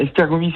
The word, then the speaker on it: Esztergomi: Alors attention, je vais essayer de prononcer correctement le nom du club hongrois, c'est Esztergomi. – Ouais, Esztergomi, c'est la ville, je dirais, et Esztergom, c'est le nom du club, il me semble.